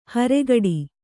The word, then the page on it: ಹೊರಕಡೆ: Kannada